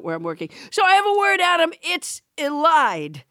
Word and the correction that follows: elide: Elied or elide? – It's elide, actually.